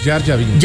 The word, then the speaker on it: Jar Jar Binks: Jar Jar Binks.